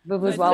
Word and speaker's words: vuvuzela: The Julie's noisemaker is a vuvuzela. – Vuvuzela.